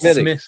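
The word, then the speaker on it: Smithwick's: In English, it's Smittix. Smithwick. It's Smithwick. So if you've ever seen what we would say is Smithwick, it's actually an Irish red called Smittix, and that's the way they pronounce it.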